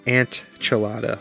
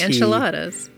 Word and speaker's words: enchiladas: Yeah, you cook enchiladas and you pronounce it antchilada, like A-N-T. – Enchiladas.